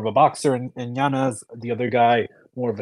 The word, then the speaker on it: Yanez: Yanez. No, no, no. He's issued a correction. He said that it, he pronounces it Yanez.